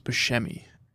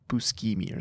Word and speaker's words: Buscemi: Yeah, you know his name's not pronounced Buscemi. It's like Buscemi or something like that.